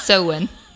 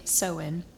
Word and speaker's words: Samhain: I've always pronounced it Samhain, but it's Samhain. – It's spelled Samhain, so I love Samhain.